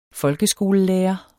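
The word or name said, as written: FOLKESKOLELÆRERE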